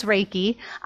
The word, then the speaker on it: Reiki: Tell me a little bit more about, and first, am I saying it right? Is it Reiki? Reiki? – That's a great question. It is Reiki.